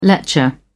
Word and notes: lecher: British English

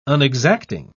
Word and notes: unexacting: British English

